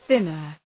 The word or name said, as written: thinner